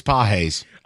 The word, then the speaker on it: Pages: I know it's spelled Pages, but it's Páez.